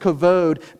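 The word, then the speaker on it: kavod (כבוד): It's pronounced kavod, but it means glory and honor, but it also means weight, weighty and heavy.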